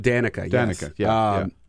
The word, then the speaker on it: Danica: Danica, is that how you pronounce it? – Danica.